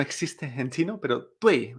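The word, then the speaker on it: duì: Y esto se pronuncia tui. Tui. Nunca tui. Esto no existe en chino.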